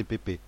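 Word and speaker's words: Pepe: Pour moi, c'est Pépé. C'est pas Pep.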